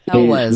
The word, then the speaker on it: Elwes: Carrie Elwes. I can never pronounce his name. From Elvis. Princess Bride. – Yeah. – Elwes.